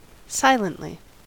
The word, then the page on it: silently: American English